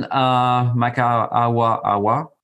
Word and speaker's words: Maka’awa’awa: So Brandon Maka’awa’awa, I hope I pronounced that correctly.